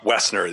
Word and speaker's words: Woessner: Woessner. The O is Woessner. – Silent. – The O is silent.